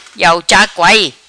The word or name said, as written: Iau Cha Kuai